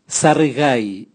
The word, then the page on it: sarigai: Raga